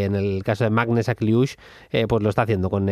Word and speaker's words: Akliouche: Para los que lo queráis apuntar y demás, se escribe con K, Akliouche. Akliouche, Magnes Akliouche o Magn Akliouche, el jugador del Mónaco.